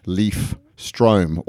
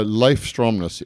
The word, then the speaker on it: Leif Stromnes: Most people call me Leif Strom or Loaf Strime, so Leif Stromnes is bang on.